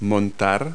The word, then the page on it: ride: verb